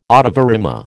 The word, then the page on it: ottava rima: American English